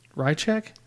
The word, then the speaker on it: Rasczak: Rico, Diz, and Ace are joining a new squad, the Roughnecks, and the lieutenant is shown to be Razak from their high school. – Radcheck. – I'm going to go with you on the pronunciation. – It's Radcheck. He's right. – Radcheck. Spelled R-A-S-C-Z-A-K.